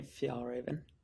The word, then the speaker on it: Fjällräven: I'm going to say it wrong. Fjällräven. How do you actually pronounce it? – Yeah, Fjällräven.